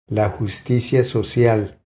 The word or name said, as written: La justicia social